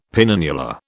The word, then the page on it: penannular: British English